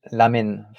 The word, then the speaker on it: ramen: Ramen ou takoyaki non, t'es pas trop mal. Juste le ER, ça se dit entre R et L, donc du coup, ça fait l'amen.